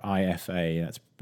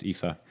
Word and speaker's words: IFA: And then the third topic we'll cover in the news roundup will be the announcements coming out of the big German tech trade show IFA, or IFA. That's typically pronounced IFA.